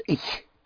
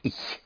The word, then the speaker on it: ich: And I want to make sure right from the beginning of how to pronounce a sound which we don't have in English, and that is the sound of the ch, ich. I is spelled ich, pronounced ich. It's a very soft sh sound. So instead of ich, make it ich, ich. That sound is more in the back of your mouth.